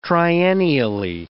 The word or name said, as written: triennially